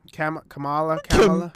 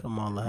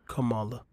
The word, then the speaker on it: Kamala: Kamala. What's her name, fam? – It's Kamala. – Kamala Harris. – You said Kamala. – Kamala. – It's Kamala. – Okay, my bad, Kamala.